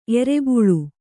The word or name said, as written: ಎರೆಬುಳು